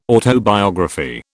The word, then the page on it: autobiography: British English